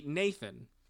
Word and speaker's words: Neithan: So Nathan is spelled N-E-I-T-H-A-N. According to the pronunciation guide, vowels that go E-I, as in Taglin, as the sound of English gray, G-R-E-Y. So N-E-I-T-H-A-N would be Nathan.